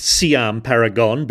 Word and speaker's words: Siam Paragon: Or as it's pronounced, Siam Paragon, but, you know, don't let that trouble you.